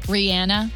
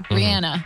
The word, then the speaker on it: Rihanna: Rihanna actually meant to be said Rihanna. And I've noticed this a lot, specifically when we go to sandals trips, because she's from Barbados. So anybody in the Caribbean pronounces her name correctly, Rihanna.